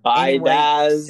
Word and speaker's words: Biadasz: But then a pick that I'm almost just as excited about the first two as the Tyre Biadasz, I think. How you say it? How you say it? Biadasz. – Biadasz.